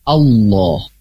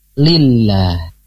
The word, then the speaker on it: Allah: Wa qala Allah. Nah, di sini pasti lafaz Allah. Kalau lafaz Allah, hukumnya pilihannya dua sahabat MK, antara ditebalkan atau ditipiskan. Tebal itu menjadi Allah, tebal. Tipis itu lillah. Itu tipis. Di sini cara bacanya tebal apa tipis?